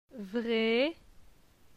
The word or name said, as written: Vre